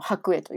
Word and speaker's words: 白衣: りますけど白い服を着た人なんだっていうことだったけどあれは白衣っていうんだね白衣じゃないんだねそう白ね私もこれねあの本当数年前まで知らなかったんですけど白衣と書いての白衣と読む